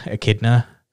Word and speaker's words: Echidna: Echidna from ReZero Season 2, which I can't believe that's how you pronounce her fucking name. – Is it? – Yeah, Echidna.